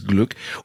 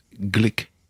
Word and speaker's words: Glück: Und sie heißt wirklich so, sie wird so geschrieben, Glück, und eigentlich ja auch so ausgesprochen, weil sie österreichisch-ungarische Wurzeln hat, Louise Glück. Aber die Amis können nicht Glück sagen. Sie sagen dann Louise Glick.